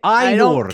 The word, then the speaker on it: Ainur: Ainur. Just roll your R's, Barry. – I can't roll my R's. It sounds like Ainur.